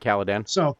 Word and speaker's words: Caladan: You came to Arrakis from Caladan. I'm probably getting that name wrong. I should know. – No, yeah, actually, no, you got it right. Caladan.